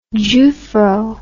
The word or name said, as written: jewfro